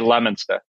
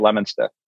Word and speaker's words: Leominster: The town I'm from is called Leominster, but you say Leominster. That's how you say it, Leominster.